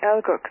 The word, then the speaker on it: ELGUX: Clear to Liverpool on the Egloo 1 Bravo departure, flight level 90, squawk 1441, Rhino 1 Alpha Alpha. – Roger, and that's the Elgux, Echo Lima Golf Uniform X-ray. – The Elgux, sorry, pronunciation wrong, Rhino 1 Alpha Alpha.